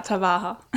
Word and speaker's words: Tahava: Äh, und ich lese mal kurz vor, das heißt Tawaha. Habe ich das richtig ausgesprochen? – Ja, Tawaha.